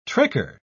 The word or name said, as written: tricker